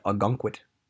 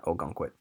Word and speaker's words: Ogunquit: I said Agunquit. I didn't say Ogunquit. Just note from a New Englander, we pronounce it Agunquit, not Ogunquit, but whatever.